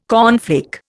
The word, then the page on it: cornflake: Indian